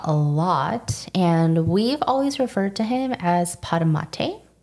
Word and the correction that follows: Patamate: but I think we've come to the realization that we are saying his name incorrectly, and I think it's Patamate.